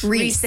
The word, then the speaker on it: Rhys: How do you pronounce R-H-Y-S? – Reese. And. – Reese? – Oh, Reese, yeah.